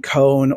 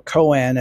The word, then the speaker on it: koan: So there's a very, very ancient and old Zen koan, or koan as it's often pronounced, and that is a riddle that's meant to awaken you to the Creator, to the divine.